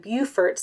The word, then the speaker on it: Beaufort: It was just, I was like, how to pronounce this? Because I don't want to say it wrong. It's Beaufort.